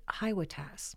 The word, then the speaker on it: HYWATAS: I call it HYWATAS, you know, the acronym for Hitch Your Wagon to a Star, rhymes with sassafras.